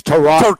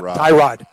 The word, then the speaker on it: Tyrod: It's Tyrod. – Tyrod. Yeah, not Tyrod.